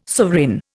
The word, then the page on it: sovereign: Indian